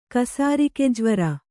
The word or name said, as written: ಕಸಾರಿಕೆಜ್ವರ